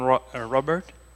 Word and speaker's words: Robert: Stefan Robert, S-T-E-P-A-P-H-A-N-E-R-O-B-E-R-T.